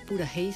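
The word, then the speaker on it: Puraheí: Ellos son Purahey Soul. Purahey se escribe P de Perú, U-R-A-H-E-I.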